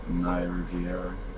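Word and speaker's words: Rivera: That was after that Naya Riveria girl, I thought. – Oh, yeah. – Riviera, I don't know her name. I butchered that, I'm sure. – Naya Riviera.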